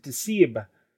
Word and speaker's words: DSCIIB: D-S-C-I-I-B, or as I'm choosing to pronounce it, Deceib, says, amazing.